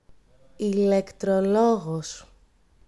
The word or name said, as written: ηλεκτρολόγος